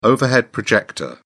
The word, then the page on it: overhead projector: British English